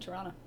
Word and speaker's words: Toronto: This is Toronto, but you pronounce it Toronto.